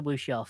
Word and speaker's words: wuxia: I was wondering how you pronounce that, because in my head, wuxia, but I'm glad you said that out loud.